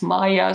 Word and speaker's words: Maja: I'm sorry, I'm not sure in Poland do you pronounce J as Y, like in my name, it's not Maja, it's Maya, so I'm not sure if it's Juliana or Juliana, but anyways.